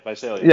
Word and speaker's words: Visalia: I don't know if I'm pronouncing this right, Visalia Oaks? – Visalia. – Yeah, got there.